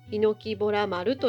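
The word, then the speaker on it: 檜洞丸: では改めて 6 月 5 日に行ってきたヒノキボラ丸登山についてお話しします。まずヒノキボラ丸の案内をさせていただきます。ヒノキボラ丸は漢字で書くと、ヒノキに洞窟の洞に丸の内の丸で、ヒノキボラ丸と読みます。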